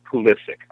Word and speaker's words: Pulisic: All right, first off, because we're going to be talking about him for a long time, let's just establish that it's Pulisic, all right? Three syllables, accent on the second syllable, and a hard C at the end.